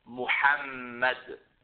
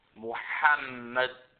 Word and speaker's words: Muhammed: Muhammed. Muhammed.